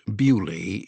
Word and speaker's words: Beaulieu: pronounced Beaulieu if you're British.